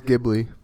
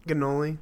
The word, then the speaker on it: Ghibli: It's Ghibli. – Ginoli. Ganoli.